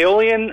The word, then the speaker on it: Aeolian: Aeolian is a Greek name.